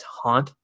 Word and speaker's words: HWNT: It was H-W-N-T, pronounced Haunt.